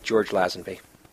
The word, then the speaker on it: Lazenby: But the middle left, I think that's a little like George Lazenby.